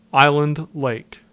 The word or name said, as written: Island Lake